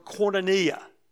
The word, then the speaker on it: koinonia: The word here in Greek is a word you've heard before, the word koinonia. If you're taking notes and don't know how to spell it, it's K-O-I-N-O-N-I-A, koinonia.